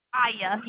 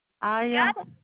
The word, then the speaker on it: Aiya: My name is Aya, and I also have your new order number if you're ready. – Ira? – Aya. Yes, ma'am. – How do you spell it? – A-I-Y-A. – A-I-A? – Yes, ma'am.